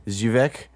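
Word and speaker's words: Żywiec: Zywicki or Zywiek? Zywiek? It's Z-Y-W-I-E-C. – Yeah, I think you said it right like the third time.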